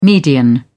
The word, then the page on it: median: British English